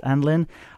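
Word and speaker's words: Annlynn: I think it's pronounced Anlin.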